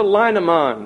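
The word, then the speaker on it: Linnemann: And I'd like to recommend just here two books by Dr. Etta Leinemann. That's the way I would pronounce her name, the way the Germans I know would pronounce it.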